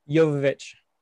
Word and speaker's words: Jovovich: Jovovich.